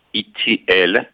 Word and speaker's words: E.T.L.: My name is Dr. E.T.L. And you pronounce E.T.L. like the letters E.T.L. You know the movie E.T.? So it's an L.